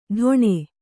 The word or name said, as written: ಢೊಣೆ